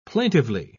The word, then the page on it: plaintively: British English